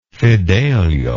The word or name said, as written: fidelio